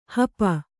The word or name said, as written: ಹಪ್ಪು